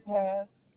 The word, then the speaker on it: Pass: Eugenia Paz. – Can you spell that last name for me? – P-A-S-S. That's P as in Paul. – You said P-A-S-S? – P-A-S-S, like pass the ball to me.